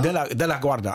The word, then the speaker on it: De La Guarda: De La Guarda? – De La Guarda, also nicht ganz wie der Flughafen.